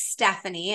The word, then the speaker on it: Stefani: So my name is Stefani.